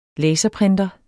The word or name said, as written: LASERPRINTEREN